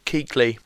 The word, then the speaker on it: Kuechly: My runner-up this week is Luke Klutschke, linebacker for Carolina Panthers. – Luke Kuechly, just to correct Luke you there, buddy.